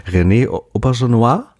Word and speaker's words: René Auberjonois: Und Star Trek haben wir hier auch vertreten mit, du kannst ihn bestimmt besser aussprechen als ich, mit meinem lässigen Nicht-Französisch, René Auberjonois? – Ja, ist das korrekt?